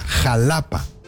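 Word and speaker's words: Xalapa: A ver, amigos, dice, se escribe con X, pero se pronuncia como J, Jalapa.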